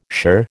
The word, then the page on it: shirr: American English